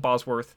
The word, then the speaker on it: Bosworth: And they have a big fight called the Battle of Bosworth.